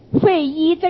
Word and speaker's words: 费祎: 侍中侍郎过忧之费祎董允等，费祎这个祎字不要读成了伪字，此皆良实自律忠诚，是以先帝简拔以遗陛下，予以为宫中之事，事无大小，细以知之，然后施行，必能皮补缺漏，有所广益也。